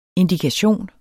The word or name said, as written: indikation